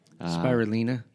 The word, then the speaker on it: spirulina: Spirulina? – It's spirulina. – Spirulina. – Spirulina. – Well, I don't know how it's pronounced. – I think you're pronouncing it right.